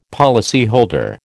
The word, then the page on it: policyholder: American English